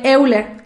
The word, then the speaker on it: Euler: Ah, es que muchos le dicen Euler, Euler, Euler, Euler. O sea, se escribe E-U-L-E-R, Euler.